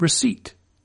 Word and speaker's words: receipt: Or how about receipt, with its now silent P, once a synonym with recipe?